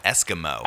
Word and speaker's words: Eskimeaux: This band is called Eskimo. – I think it's not spelled correctly. – It's spelled like it'd be like French Louisiana spelling. Or right. It's probably just Eskimo. French spelling. Eskimeaux.